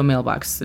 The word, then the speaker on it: mailbox: En inglés no, en inglés siempre ha sido mailbox. La pronunciación fonética es M-E-I-L-B-A-X.